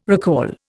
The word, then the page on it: recall: Indian